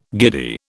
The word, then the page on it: giddy: British English